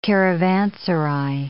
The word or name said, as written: caravanserai